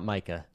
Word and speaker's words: MiCA: And you guys say MiCA, not MICA. – Excuse me. – I don't know, what is the right pronunciation? Give it to us. – I've always said MiCA. – MiCA. All right, perfect.